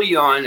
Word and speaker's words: yuan: Do you have the news on the digital yuan? – Oh, that's I can take it. – All right, so the digital yuan has, am I saying that yuan? – Yuan.